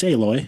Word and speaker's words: Aloy: Aloy's grandmother from Horizon Zero Dawn. – Aloy. – What would I say? – Aloy. It's Aloy.